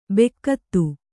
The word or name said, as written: ಬೆಕ್ಕತ್ತು